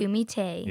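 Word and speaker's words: Takumi-Tei: And we're going to talk about another one later, but this one is, can you pronounce it? – Takumite. – Yes, I believe it's Takumite.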